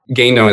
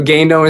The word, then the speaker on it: Guindon: Guindon is the French last name. I don't really speak French, but my wife does. So Guindon is spelled G-U-I-N-D-O-N.